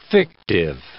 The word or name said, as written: fictive